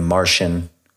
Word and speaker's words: Marcion: You know, some people pronounce his name Martian.